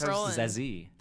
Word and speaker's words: Zazie: It's pronounced Zazie.